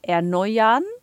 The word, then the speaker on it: erneuern: Yeah, it's tricky because it's easy to say erneuern, just like kartoffeln. But okay. – What you can do is just not pronounce the R at the end, but turn it more into an A sound, so erneuern. – Ah, right, okay. – Yeah, erneuern. – Erneuern. – And then you don't have this extra syllable that goes in there.